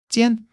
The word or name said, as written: jian1